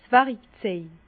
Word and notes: 's Wärikzej: Bas-Rhin; Herrlisheim

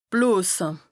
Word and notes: blose: Bas-Rhin; Strasbourg

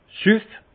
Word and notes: süff!: Haut-Rhin; Munster

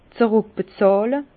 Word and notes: zeruckbezàhle: Alsatian; Bas-Rhin; Herrlisheim

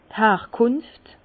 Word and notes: d' Herkùnft: Bas-Rhin; Herrlisheim

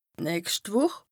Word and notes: d' nächscht Wùch: Bas-Rhin; Strasbourg

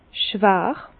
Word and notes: schwàr: Bas-Rhin; Herrlisheim

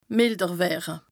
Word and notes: mìlder wëre: Bas-Rhin; Strasbourg

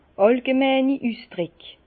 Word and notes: àllgemeini Üsdrick: Bas Rhin; Herrlisheim

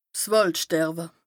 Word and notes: Wàldsterwe: Bas Rhin

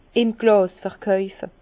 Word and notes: im Glàs verkaufe: Bas-Rhin; Herrlisheim

